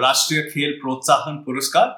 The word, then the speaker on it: Rashtriya Khel Protsahan Puraskar: Do you want to say the correct pronunciation? – Yeah, the Rashtriya Khel Protsahan Puraskar.